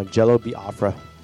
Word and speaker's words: Jello Biafra: Yeah, Jello Biafra.